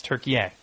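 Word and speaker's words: Türkiye: It's, yeah, that is how it's spelled. But they now say the pronunciation is Turkey.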